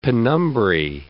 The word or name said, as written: penumbrae